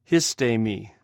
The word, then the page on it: histhm: Greek